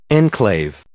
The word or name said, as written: enclave